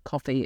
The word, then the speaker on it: Ko-fi: Coffee. It's pronounced coffee. You're right.